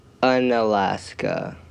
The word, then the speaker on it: Unalaska: Unalaska.